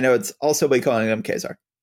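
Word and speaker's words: Ka-Zar: And so he's like, okay, just to get this out of the way, it's pronounced Kazar.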